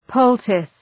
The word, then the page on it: poultice: ‘pəʋltıs